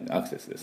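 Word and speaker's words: access: これはですね、アクセス、このウェブサイトにアクセスしてください、アクセスですね。これは同じ意味で同じあの読みで発音なんですが、ちょっと微妙に発音が違います。正しい発音しますとアクセス、アクセス、 A のところをミャーの音ですね。でそれでミャーでアクセスで、アクセス、アクセスと U をつけないように S で終わるよね。アクセス、アクセスっていう音です。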